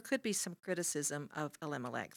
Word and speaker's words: Elimelech: Naomi, just family, and Abimelech. Lived in, did I say that right? – Elimelech. – Yeah, E-L.